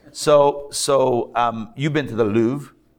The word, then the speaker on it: Louvre: It's like, I don't know, go to the Louvre. Is that how we pronounce it? The Louvre.